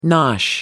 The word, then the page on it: nosh: American English